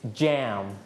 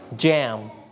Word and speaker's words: jam: both are correct if you call it in Thai you may call it like yam but in English you can call it like jam again please jam